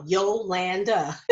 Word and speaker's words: Yolanda: Even though some people like to say Yolanda, I'm like, no, it is, my name is spelled, it sounds the way it's spelled, because it's the A-N-D-A after the Y-O-L, Yolanda.